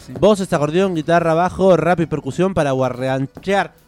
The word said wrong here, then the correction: guarachar: guarachar, ¿lo digo bien? – Sin, guarachar.